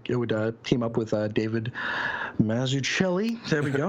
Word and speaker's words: Mazzucchelli: It's like Massachusetts. Now, David Mazzucchelli,